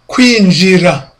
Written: kwinjira

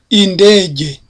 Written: indege

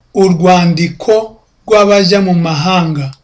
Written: urwandikorwabajyamumahanga

